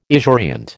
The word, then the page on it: esurient: American English